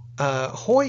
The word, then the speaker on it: Haughey: Hoy is not how I would have pronounced it. – No, it's got seven letters and it's only one syllable.